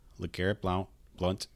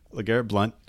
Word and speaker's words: LeGarrette Blount: LeGarrette Blount, Adrian Peterson, CJ Anderson, Kenny Britt, DeSean Jackson, Kyle Rudolph, and then Kareem Hunt.